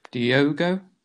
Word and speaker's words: Diogo: How did you say his name against you? – Diogo? – Yeah, just checking. – Thanks, there. Perfect in your Portuguese.